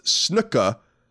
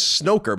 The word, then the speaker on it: snooker: Snooker is pronounced snooka, like snooka, and he says snooker, but that's not how he said it.